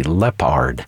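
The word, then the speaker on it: Leppard: A name that we frequently announce is the conductor Raymond Lepard. Well, that's not spelled like the jungle animal, L-E-O, it's L-E-P-P-A-R-D, Lepard.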